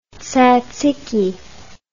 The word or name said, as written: tzatziki